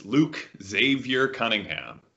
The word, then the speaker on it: Luke Xavier Cunningham: Yes, Luke Xavier Cunningham, the maximum Catholic name that my parents could give me.